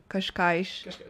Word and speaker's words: Cascais: Hence my mispronunciation of Cascais. – No, you did quite well with that one.